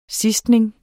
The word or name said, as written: sidstning